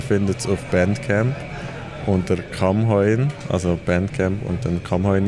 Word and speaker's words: Kammheuen: Also ich muss ganz leicht berichtigen, es ist Kamm heuer.